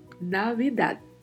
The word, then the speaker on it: Navidad: Temos aí particularidade de pronúncia. Mais uma vez, V com leve som de B, temos falado bastante disso nos últimos podcasts. E ao final da palavra temos um D que é mudo, Navidad. Navidad.